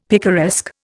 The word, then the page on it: picaresque: Indian